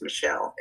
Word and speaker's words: Michel: So then I was ready to like rename myself like completely because my first name is Michelle, M-I-C-H-E-L. It's spelled like Mike, right? – Mm-hmm.